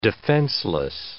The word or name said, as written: defenseless